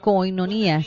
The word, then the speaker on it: koinonia: Es esta palabra complicada que tenemos ahí en la pantalla y es koinonia. Y bueno, no es tan complicada, pero es koinonia, se pronuncia.